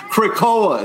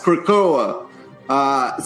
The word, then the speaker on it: Krakoa: What writer is excited for Deadpool to invade Kuroka? Why am I saying that wrong? – Karaoke. It's pronounced karaoke. – No, it's not. Krakoa. It's Krakoa.